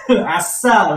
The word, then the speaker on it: 아싸: You succeed about something, At that time, you can shout "assa" like that. Last syllable, you have to pronounce strongly.